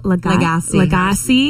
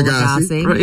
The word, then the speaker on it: Lagasse: Celebrity chef, help me with the pronunciation here. Emeril Lagasse. – Bam! – Yeah. 67 today. – Who coins bam? Who? – Emeril Lagasse.